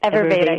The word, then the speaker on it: Ayurveda: When we come back, I'd like to go in a little bit more in depth at what you actually do at Ayurveda. Is that how you say it? – Ayurveda. Yeah. Thanks.